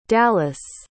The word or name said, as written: dalis